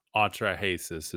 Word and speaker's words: Atrahasis: Atrahasis is how I pronounce it.